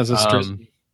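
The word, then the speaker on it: Zastryzny: Pitchers is Corey Black, David Garner, Pierce Johnson, Rob Zestrinsny. I don't know how to say that correctly. – You said it right. Yeah, Zestrinsny.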